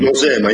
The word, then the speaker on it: Nosema: In the article it's mentioned the gut parasite, Nosema cernai, I probably butchered that name. – Nosema, yes, Nosema.